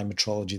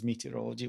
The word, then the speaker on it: metrology: We obviously need to clarify metrology these days in a way that we never thought we'd have to. People still mispronounce it as meteorology, which is very frustrating.